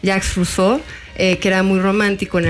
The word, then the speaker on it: Rousseau: Es que lo pronunciamos mal, es Rousseau.